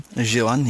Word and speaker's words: Ju-on: But I watched, is it pronounced Ju-on? – Ju-on, yeah.